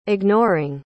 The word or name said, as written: ignoring